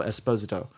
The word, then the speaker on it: Esposito: We start off in the season that we get Moff Gideon, Giancarlo Esposito is how he pronounces his name, actually. – It's Esposito. – Yeah, we always do Giancarlo Esposito, but he said it's actually pronounced Giancarlo Esposito.